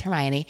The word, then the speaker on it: Hermione: So now we all know that it's Hermione.